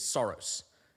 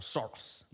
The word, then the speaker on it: Soros: The reason is my last name is Soros, or Soros. I'm never quite sure how to pronounce it. Soros.